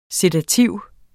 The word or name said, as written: sedativ